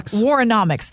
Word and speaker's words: Warrenomics: When you're hearing things like Warrenomics. – Warrenomics. – Thank you very much. – Yeah. – You got the pronunciation right. Warrenomics.